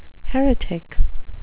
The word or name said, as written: heretic